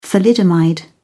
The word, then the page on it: thalidomide: British English